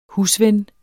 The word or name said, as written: husven